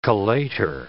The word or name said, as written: collator